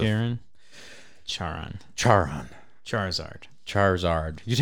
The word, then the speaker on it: Charon: I don't know how you say his name. Is it Sharon, the guy that the boat? – Yeah. – Yeah. Chiron. Chiron. Yeah. Sharon.